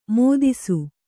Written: ಮದಿಸು

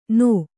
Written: ಣ

